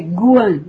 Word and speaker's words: Kuan: K-U-A-N, Y-I-N. A pronúncia de Kuan no canto do mantra chinês é Guan, G.